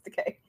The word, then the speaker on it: Takei: Or is it Takei? Am I saying it wrong? – Takei, I believe. But I didn't want to correct you because I'm nice. – Aw, you're so nice. Okay, it's Takei.